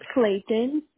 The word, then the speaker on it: Clayton: It's Clayton, C-L-A. Y-T-O-N.